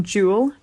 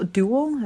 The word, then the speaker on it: Dual: I just got to see the film Jewel or Duel, Duel, as Americans say, uh, which is a film I made in Finland last year with the director Riley Stearns.